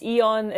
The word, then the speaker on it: E.ON: So today with us we have currently an investment principal at E.ON, is that correct? How do you say it? – Yeah, it's E.ON.